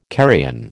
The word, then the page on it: carrion: American English